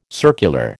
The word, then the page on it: circular: American English